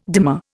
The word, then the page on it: dimer: Indian